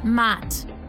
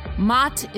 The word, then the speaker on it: Maat: Since the theme today is Kemetic, why not stick with it and talk about the ancient Egyptian goddess Maat? Her name is spelled M-A-A-T.